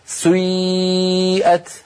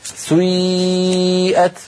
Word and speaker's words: سيئت: كلمة سيئت أصلها سيئت، سيئت على وزن فعل، لأن هذه الأفعال سيئة وأخواتها أفعال مبنية للمجهول، فبعض القبائل العربية كانوا ينطقونها بيبدأون بضمة ثم يعودون إلى الخلف فينطقون ياء هكذا سيئت. هذه الحركة يسميها العلماء حركة مركبة، مركبة يعني من حركتين أولها ضمة وآخرها كسرة كما نطقتها لك سيئت وجوه الذين كفروا، هكذا تنطق يا أخي الكريم.